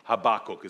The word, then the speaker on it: Habakkuk: By the way, until I was in Bible college, I actually didn't know how you pronounce that word, but Habakkuk is apparently how you do it.